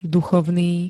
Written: duchovný

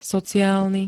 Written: sociálny

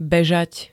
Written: bežať

